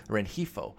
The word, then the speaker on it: Rengifo: I'm pronouncing the G like a total noob-ass noob, Rengifo.